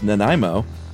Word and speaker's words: Nanaimo: And is pronounced Nanaimo.